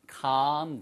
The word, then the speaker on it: calm: 얘는 calm down. L이 묵음이에요.